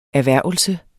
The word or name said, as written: erhvervelse